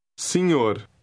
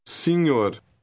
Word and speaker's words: senhor: The letter combination NH is pronounced like the middle of the word onion. Listen and repeat. – Senhor. Senhor.